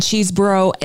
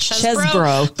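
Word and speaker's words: Chesebro: But one thing we've learned in this process is it's actually pronounced Chesbrough. But of course, everyone is still calling it Cheeseborough because it makes more sense.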